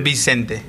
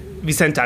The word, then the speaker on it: Vicente: Vicente Matthias Diaz Silva oder äh, Vicente, eher Spanisch. – Genau, Vicente Matías Díaz Silva. – Ja, und das, das erste? Äh, Vicente. Vicente, also Spanisch.